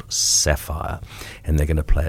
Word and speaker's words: Saffire: Their name is Sapphire, but you spell it S-A-F-F-I-R-E.